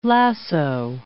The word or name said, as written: lasso